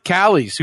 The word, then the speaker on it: Callies: Callies,